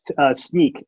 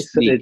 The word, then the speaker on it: Snyk: We call it Snyk, S-N-Y-K. Sneak.